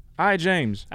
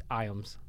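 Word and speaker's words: Ijames: But it's actually spelled, it's pronounced Iams. I was like, Iams? – Well, I guess the J was kind of like silent or something.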